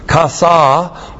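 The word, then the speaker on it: Kasa (חָסָה): Conjunction key joined by a makef to the cal perfect of kasa plus the preposition baith with the second person singular masculine suffix.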